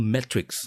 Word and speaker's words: metrics: ย่างสามารถเป็นมชเชอร์ได้หมดทีนี้คำต่อมาเนาะที่อยากจะพูดถึงคือคาว่า metrics